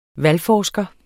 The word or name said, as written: valgforsker